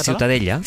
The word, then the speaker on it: Ciutadella: Para mí la Ciutadella es... ¿cómo se pronuncia? – La Ciutadella.